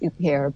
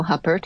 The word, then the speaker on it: Huppert: I know it's actually pronounced Huppert, but a lot of Anglophones call her Isabel Huppert because that's how it's spelled, the actor.